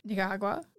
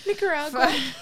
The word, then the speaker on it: Nicaragua: So he's taking guns to Nicaragua. Ni- fucking can't pronounce that name. Um, I was like writing it out. I'm like, oh no. I think it sounded fine. – Nicaragua.